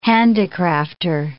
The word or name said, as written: handicrafter